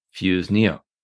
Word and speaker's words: Fuseneo: Well, I know your background a little bit better now, but I'd love to hear about Fuseneo.